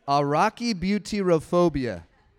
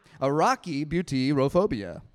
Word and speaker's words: arachibutyrophobia: All right, here's the pronunciation. Ready? I really practiced this multiple times. Arachibutyrophobia. Arachibutyrophobia. You'll sound like that guy that I listened to. Arachibutyrophobia.